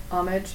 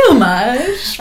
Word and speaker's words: homage: I just learned that I pronounced it really pretentiously. – I say homage, but Tessa had called me out on it. – Really? – I looked it up. Yeah, it's like the snootiest pronunciation of it. It's homage.